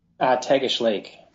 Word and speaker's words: Tagish Lake: how do you pronounce it, the Tag? – Tagish Lake.